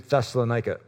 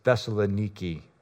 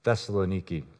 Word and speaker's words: Thessaloniki: They went from Philippi to Thessalonica, which, by the way, my Greek mother told me I should make sure I'm pronouncing it correctly. The correct pronunciation is Thessaloniki. Thessaloniki.